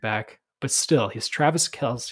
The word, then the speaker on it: Kelce: It's actually pronounced Travis Kelce.